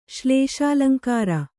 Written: ಶ್ಲೇಷಾಲಂಕಾರ

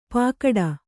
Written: ಪಾಕಡ

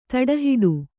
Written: ತಡೆಹಿಡಿ

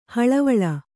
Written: ಹಳವಳ

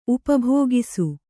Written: ಉಪಭೋಗಿಸು

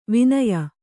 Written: ವಾನೇಯ

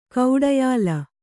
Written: ಗಾಢಾಯ್ಲ

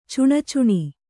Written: ಚುಣಚುಣಿ

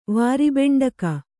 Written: ವಾರಿಬೆಂಡಕ

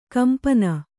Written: ಕೆಂಪನೆ

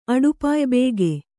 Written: ಅಡುಪಾಯ್ಬೇಗೆ

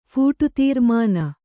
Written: ಫೂಟುತೀರ್ಮಾನ

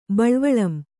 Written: ಬಳ್ವಳಂ

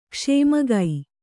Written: ಕ್ಷೇಮಗೈ

